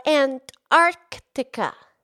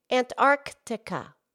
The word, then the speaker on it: Antarctica: Yeah, there's an extra C in there. Antarctica. Antarctica. – It's a little tricky, huh? – Yeah, it has the word arc.